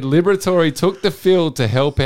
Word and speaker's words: Liberatore: Liberatore.